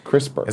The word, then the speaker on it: CRISPR: CRISPR.